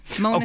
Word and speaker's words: Måneskin: Oh yeah, Maneskin. – Yeah, I forgot them. – Måneskin. – Okay. Yeah, that's how you pronounce it, supposedly.